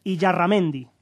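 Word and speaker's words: Illarramendi: Asier, a ver si lo digo bien, y Yarramendi. – Bien.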